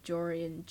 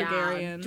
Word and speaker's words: Gregorian: My dad was pronouncing it Georgian. – That's not right. It's definitely Gregorian.